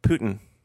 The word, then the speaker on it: poutine: Well, let's go get poutine, and then because Nelly's lived in Canada before, and she was like, "It's actually pronounced like Putin," and I went, "It's good to know."